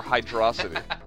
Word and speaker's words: Hydrocity: Is it Hydro City or Hydrocity? – I've been trying really hard to make sure that I say Hydro City, because that's definitely how it's pronounced.